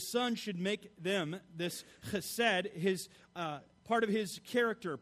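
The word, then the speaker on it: chesed (חסד): Is that right, Joel? Is that chesed? Am I right? All right, I got to say it correctly, even though I'm spitting all over the place. But it's chesed, and it summarizes faithfulness and godliness in contrast to the selfish malice and infidelity of the wicked.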